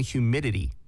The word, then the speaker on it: humidity: I think, humidity 발음은 그, you know, the American way of like words with T like in the middle, mm-hmm. like you don't really pronounce the T. Humidity라고 하는 humidity.